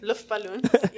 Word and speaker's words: Luftballons: I can't say it like that. – Luftballons. Yeah.